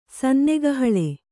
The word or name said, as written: ಸನ್ನೆಗಹಳೆ